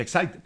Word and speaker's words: Exide: Se escribe Exide, se pronuncia Exide, decía el comercial de las baterías.